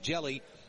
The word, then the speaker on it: Hjelle: He is 6'11", spelling it H-J-E-L-L-E, pronounced Jelly, and he's from Fridley, Minnesota.